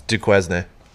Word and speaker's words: Duquesne: And I don't know if I'm saying this right. You might have to let me know. – It's Duquesne.